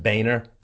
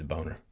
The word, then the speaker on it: Boehner: The representative who helped lead the ouster of Speaker John, yes, I know, it's supposed to be pronounced Boehner, but look at that spelling! It's a boner.